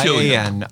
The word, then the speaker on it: Cillian: It starts at C-I-L-L-I-A-N. Killian. I believe Killian. Is pronounced Cillian.